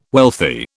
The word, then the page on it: wealthy: British English